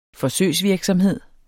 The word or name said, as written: forsøgsvirksomhed